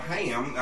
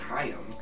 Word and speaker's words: Haim: And I got this singer with him called Haim. I hope I'm pronouncing that right. Haim. – Haim. – It's H-A-I-M.